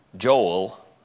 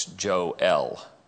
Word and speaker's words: Joel: And we say Joel, but it's not Joel, it's Joel.